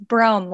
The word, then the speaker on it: Brougham: Yeah, it's Brougham, like Rome with a B.